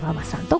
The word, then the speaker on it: VAVA: そして南青山にフレンチレストランシルクさん、 CILQ と書いてシルクさん、そして霞が関にエビ専門店シュリンプガーデンさん、そして日本橋高島屋さん新館にございますカフェババさん、 VAVA と書いてね、カフェババさんとございまして、この中からですね、今月は銀座にございますシーフードレストラン火山さんのペアお食事券 8000 分となっております。